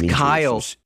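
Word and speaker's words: Cyle: Sile, his name is Kyle. Sile. No, it's Kyle. – Where'd you see the K in that name? – It's a hard C.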